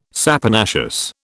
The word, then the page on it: saponaceous: British English